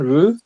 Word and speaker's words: Rue: Habe ich richtig ausgesprochen, Rue? – Ja, ja, sicher.